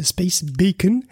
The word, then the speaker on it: Space Beacon: Et oui, c'est prononcé Space Beacon.